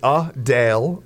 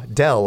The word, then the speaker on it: Adele: But it's Adele. Adele. Yep. Not Adele, like I just did.